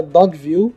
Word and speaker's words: Dogville: Chama Dogville, pronunciado tudo junto em inglês.